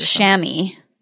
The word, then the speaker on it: chamois: And it's pronounced chamois.